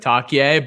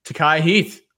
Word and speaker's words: Takai Heath: And I think we had mispronounced it earlier this season, maybe like Takie, but Takai Heath,